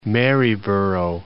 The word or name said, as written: Maryborough